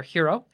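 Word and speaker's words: Heroux: Sorry, he pronounces it, it's pronounced Mayor Hiro. H-E-R-E-O-U-X? O-U-X? – Yeah.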